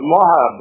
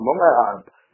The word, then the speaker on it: Moab: Ah, so it's Moab. I wanted to make sure, that's why I asked first, because I wanted to make sure I got the pronunciation right. It's Moab. Moab. – That's correct, yeah.